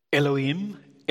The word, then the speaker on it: Elohim: Elohim, Elohim.